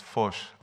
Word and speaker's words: Foch: And the actual French pronunciation is Foch, Avenue Foch.